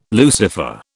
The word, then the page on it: Lucifer: British English